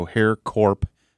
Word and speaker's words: HERRCORP: It's HERRCORP, so Hair Corp. –.com. – It's interesting it rhymes with your name of Hair Corp. Not spelled the same.